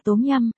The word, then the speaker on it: tom yum: Tom yum hay tom yum, phát âm theo tiếng Việt là tốm yum, là tên của loại canh chua cay ăn nóng đặc trưng trong ẩm thực Thái Lan, Lào.